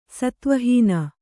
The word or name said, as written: ಸತ್ವಹೀನೆ